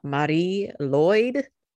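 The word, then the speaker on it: Mari Lwyd: I mean, at least to me, but it's pronounced Marie Lloyd. – Mm. – Kind of like Marie, you know, but you kind of roll the R a little bit.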